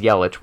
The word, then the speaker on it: Yelich: So the idea that $30 million to get Giancarlo Stanton and to get Yelich, it is Yelich. We've had two different pronunciations said on this podcast, so now I can confirm.